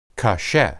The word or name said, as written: kasheh